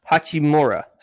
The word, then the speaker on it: Hachimura: I swear it's Huchimara. Yeah, Hachimura.